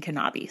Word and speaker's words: Kenabi: So there's one death that's really related to the building, and that's Dr. Helene Kenabi, which it looks like it's spelled like Nabe, but there's a K. And then, so because Ghost Hunters was here last year and they were saying Kenabi. So that's the pronunciation I'm going with.